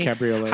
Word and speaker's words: Cabriolet: It is a 1937 Mercedes-Benz 540K Cabriolet. I think I pronounced that correctly. – Cabriolet.